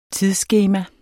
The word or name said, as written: tidsskema